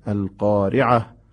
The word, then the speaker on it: القارعة: دقت بفرمایید، تای گردی که در انتهای قارعه هست باید در هنگام وقف تبدیل به هاء بشه. القارعه مل قارعه وما أدراك ما القارعه. حالا اگه وصل کنیم چی میشه؟ نه، این ت تلفظ میشه، ت هم خونده میشه. القارعه ما القارعه وما ادراک ما القارعه، به این شکل. اگه وصف بشه تا تلفظ میشه، اگه وقف کنید میشه ه، که ما توصیه‌مون عرض کردم بیشتر وقفه.